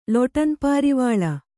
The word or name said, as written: ಲೊಟನ್ಪಾರಿವಾಳ